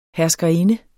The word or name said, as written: herskerinde